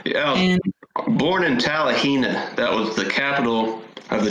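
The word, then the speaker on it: Talihina: Tallahena, Oklahoma. Tallahena.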